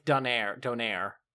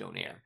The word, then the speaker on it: donair: It's pronounced kind of like a donor, like donair. But they... – Yeah, I know. It's supposed to be pronounced donair.